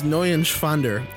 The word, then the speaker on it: Neuschwander: You didn't pronounce it right. Anthony Neuschwander. – It's N-E-U-E-N. It's Neuschwander.